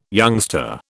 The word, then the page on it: youngster: British English